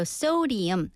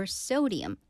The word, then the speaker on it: sodium: So sodium, 소듐이지만 영어 발음은 sodium입니다. So no added salt or sodium content.